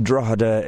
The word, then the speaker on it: Drogheda: Or Drogheda, D-R-A-W-D-A, as I like to pronounce it. But it's pronounced Drogheda if you are a posh person.